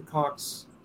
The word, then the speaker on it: Koch: You know, people like the Cox, I think that's how you pronounce it, K-O-C-H brothers. You know, I think that's it. Yes.